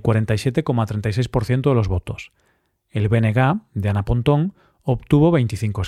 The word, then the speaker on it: BNG: Por cierto, cuando pronuncio BNG, Bloque Nacionalista Galego, no digo BNG, porque la letra G en gallego se pronuncia así, GA, y así es como se le llama al partido, BNG.